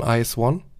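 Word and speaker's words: IZ*ONE: Kennst du die Gruppe Ice One? Also ja. Äh, die ist IZ Sternchen und dann genau also. und die werden aber ausgesprochen Ice One.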